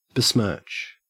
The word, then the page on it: besmirch: Australian English